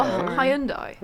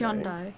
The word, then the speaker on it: Hyundai: A Hyundai Elantra. – A what? – Hybrid. It's a hybrid Korean. – Oh, a Hyundai. – Okay. Hyundai. Hyundai. – Hyundai. – Hyundai. – We're pronouncing it right.